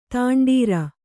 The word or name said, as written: ದಂಡೋರೆ